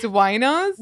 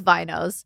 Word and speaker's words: vinos: Wait, it's off the vine, so it's winos? – No, it's vinos.